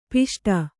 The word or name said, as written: ಪುಷ್ಟ